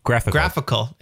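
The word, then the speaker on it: GraphiQL: Is like, it's like graphical. How do you say that? Like the graphical.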